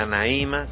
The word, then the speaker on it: Anaíma: Entonces, queremos invitarlos a que sigan las redes sociales, tanto de la universidad como de Anaíma, con acento en la I, para realizar todo esto y que vean todo lo que se está logrando poniendo en alto a la UACJ.